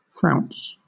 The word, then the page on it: frounce: British English